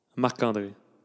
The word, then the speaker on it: Marc André: And I will tell you guys how you say it in French. – Oh, okay. – Marc André.